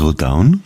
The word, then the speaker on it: Rodaun: Das war die Wunschnummer von unserem heutigen Gast, Herr Beppo, seines Zeichens Bademeister im Strandbad Rodaun, habe ich das richtig gesagt? – Völlig richtig, ja.